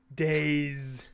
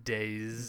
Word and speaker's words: DayZ: A lot of people call it DayZ, but I don't think that's the right way to say it. – It's Days.